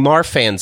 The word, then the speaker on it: Marfan: You talk about having Marfan syndrome. Am I pronouncing that correctly? – Yeah, you were correct.